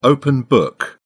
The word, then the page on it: open book: British English